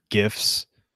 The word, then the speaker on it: GIF: I really got to see were some GIFs. And yes, that's GIFs with a hard G because it stands for graphic interchange format.